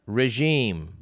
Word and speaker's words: regime: The word G-A-L-A, you may pronounce it gala. It's correctly gala. R-E-G-I-M-E, you may pronounce it regime, but the first syllable is supposed to sound as ray, regime.